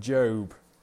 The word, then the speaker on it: Job: Pronunciation, Job.